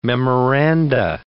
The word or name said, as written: memoranda